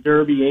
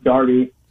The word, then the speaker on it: Derby: I mean, he started out as a player coach in Derby, England, and actually, it's pronounced Darby, and then just kind of went everywhere along the way.